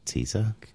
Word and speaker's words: Caesar: Caesar, Herr Caesar? – Also es wird an sich Kaiser ausgesprochen. – Kaiser? – Aber Caesar ist okay.